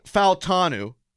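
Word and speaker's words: Fautanu: Your starting offensive line this upcoming season could be Troy Faltanu. That's how you say his name, by the way. I got Faltanu. – Okay. – Because they released the pronunciation from his mouth.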